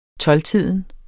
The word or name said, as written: tolvtiden